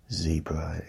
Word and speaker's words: zebra: But I know that zebra is used in some places. But the correct pronunciation is, the correct pronunciation is zebra. Ze, not z, ze, ze.